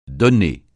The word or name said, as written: donné